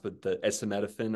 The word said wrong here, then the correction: acetaminophen: or whatever it's called. – Yeah. Tylenol, acetaminophen.